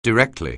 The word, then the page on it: directly: anglais